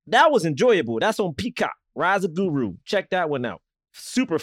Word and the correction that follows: Gru: You mean Rise of Gru, right? Rise of Gru. – How you say his name? I thought it was Guru. How you say his name? – No, it's just Gru.